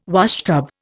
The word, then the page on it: washtub: Indian